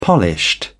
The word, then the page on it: polished: British English